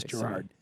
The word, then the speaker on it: Gerard: Is Gerard? – Okay. – Now, saying that, there are people who go with the hard G. – The hard G. – Those people are insane.